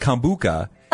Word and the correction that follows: kombucha: Oh. – And it's kombucha. Correct? – Yes. You're right. You're just, again, the emphasis, it's in the wrong place. Oh. – The emphasis is on the wrong syllable.